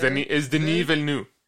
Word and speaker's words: Denis Villeneuve: No, no, no. Es Denis Villeneuve. Denis Villeneuve. – Ah, sí. Villeneuve.